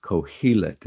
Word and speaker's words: Qohelet: But the Hebrew name for the narrator is Kohelet, that's spelled Q-O-H-E-L-E-T, and it's pronounced Kohelet.